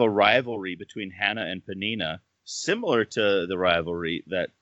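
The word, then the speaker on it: Peninnah: He had one wife named Hannah, who had no children, and I'm going to butcher this name, Panina. Does that sound right? – Yeah, that's what I came up with in my head.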